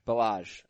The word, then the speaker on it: Ballage: Kalen Ballage. – Ballage. Okay. Like Bellagio in Las Vegas.